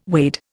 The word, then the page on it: wade: Indian